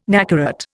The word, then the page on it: nacarat: Indian